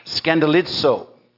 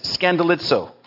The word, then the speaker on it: skandalizo: And it's pronounced skandalizo. I don't know how you pronounce it by looking at that way, but that's what it says, skandalizo, which is the word we get in English for our word to scandalize, or the word for scandal.